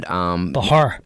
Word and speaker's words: Bachar: So my guest is Dian Bachar and Bachar. – Bachar. I always pronounce the C.